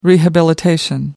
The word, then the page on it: rehabilitation: British English